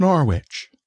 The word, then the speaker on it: Norwich: We have a Norwich, but we pronounce it Norwich.